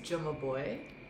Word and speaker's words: Jumabhoy: So the H is silent. That's why I get a lot of Jumabhoy. but the A is before the B, so it doesn't really make sense.